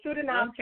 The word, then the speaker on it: Sedinam: Okay, my first name, S-E-D-I-N-A-M. So it's Sudanam 2020. We also have a website, Sudanam 2020, S-E-D-I-N-A-M, Sudanam 2020.